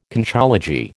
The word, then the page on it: Conchology: American English